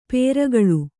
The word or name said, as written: ಪೆರ್ಕಳ